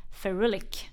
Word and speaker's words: Ferulic: det märket också precis kommit med en ny produkt som heter Meso Aesthetic AOX Ferulic. Ferulic. Eh, och Helt rätt uttalat.